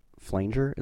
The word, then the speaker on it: flanger: There's a lot of flanger in the... Is that how you say it? – F-L-A-N-G-E-R on guitar.